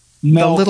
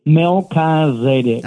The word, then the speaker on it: Melchizedek: Melchizedek. The little book. Zadek, long I, long E. – Kazadek. – Kazadek, Melchizedek. – Okay.